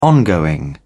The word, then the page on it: ongoing: British English